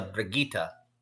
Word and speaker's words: Brigitte: Bridget from Overwatch coming in at 5'11". – It's actually a Brigitte. She's going in the Gaelic pronunciation.